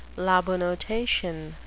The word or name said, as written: labanotation